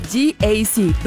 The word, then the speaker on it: GAC: Se escribe GAC, pero se pronuncia GAC.